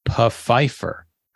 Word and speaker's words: Pfeiffer: That Desmond Pfeiffer show, they pronounced the P in the last name. Pfeiffer.